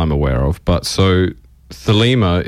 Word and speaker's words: Thelema: Thelema, correct, yes. And that is also the correct pronunciation because there's two, Thelema and Thelema.